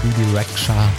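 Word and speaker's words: Bebe Rexha: New Religion von Bibi Rexha, oder wie sie in ihrer Heimat gerne ausgesprochen wird, Albanien, Bibi Rexha.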